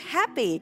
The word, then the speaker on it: happy: Ở đây mình không đọc là hep ha, mình đọc là hap. Và nó là happy.